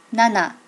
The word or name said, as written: nana